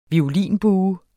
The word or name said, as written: VIOLINBUER